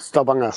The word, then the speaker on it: Stavanger: hier Richtung Starwanger gefahren wie spricht man das aus? Stavanger? Stavanger? Ja, Stavanger.